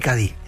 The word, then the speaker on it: Cádiz: Escritor de Cádiz y Cádiz. ¿Se dice bien así? Sí, señor. – ¿Lo he dicho bien? – Lo ha dicho perfecto.